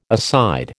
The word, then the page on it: aside: American English